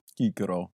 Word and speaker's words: Cicero: Cicero.